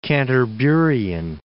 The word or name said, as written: Canterburian